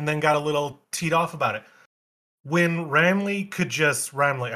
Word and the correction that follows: Raimi: how do you say his last name? – Raimi. – Raimi.